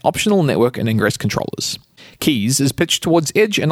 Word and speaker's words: K3s: Rancher Labs has announced Keys, spelt K3S, and as they haven't provided a pronunciation guide, I get to decide how it's said out loud.